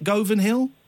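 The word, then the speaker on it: Govanhill: Is that how you say it? Govan Hill? – Govan Hill.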